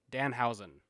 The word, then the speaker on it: Danhausen: Yeah, um, uh, I think the biggest takeaway from this night is that Orange Cassidy and how do you say his name? – Danhausen. – Danhausen.